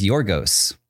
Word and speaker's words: Yorgos: His name is Yorgos. – Yorgos? – Yeah, Yorgos. – Love that. – Cool name, right? Yorgos? – So cool. – Y-O-R-G-O-S. – I love that name.